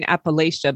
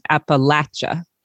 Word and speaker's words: Appalachia: And I'm saying it wrong. I'm saying Appalachia, but you're saying Appalachia.